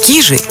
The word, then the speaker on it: Кижи: В Заонежье традиционно используется ударение на первый слог – «кижи». Однако в современности более распространено название с ударением на второй слог, которое филологами признано общепринятым.